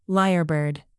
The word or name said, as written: lyrebird